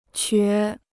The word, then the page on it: 瘸: qué